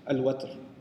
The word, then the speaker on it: وتر: Which is why we find in the hadith, فَإِنَّ اللَّهَ تَعَالَى وَاحِدٌ وَتْرٌ, or witr also. Watr is also pronounced witr, but it's also it's argued in Lisan al-Arab that the better pronunciation is al-watr.